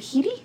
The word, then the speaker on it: Headey: Is it Headey? – Mm-hmm. – I thought there was an L in it.